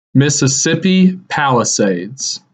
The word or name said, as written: Mississippi Palisades